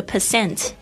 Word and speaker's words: percent: 99% 就是 99%，这个 percent 它之前有那个符号哦，就是不要再把它念成趴了， OK。